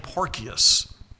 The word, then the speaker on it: Porcius: But after 2 years, Porcius Festus, and yes, that's how you say his name, Porcius.